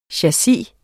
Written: CHASSISER

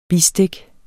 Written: bistik

